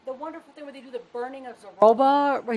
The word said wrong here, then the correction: Zozobra: is that how you say it? – Zozobra. That's the night before we start.